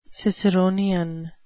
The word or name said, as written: ciceronian